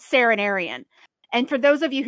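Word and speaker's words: Seren Arian: It's S-E-R, I will spell it for you. S-E-R-E-N, Saren, Arian, A-R-I-A-N, Serenarian.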